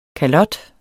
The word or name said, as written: kalots